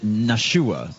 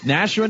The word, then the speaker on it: Nashua: which anyone who outside of the area pronounces it Nashua, but it's Nashua, New Hampshire, in the Live Free or Die state.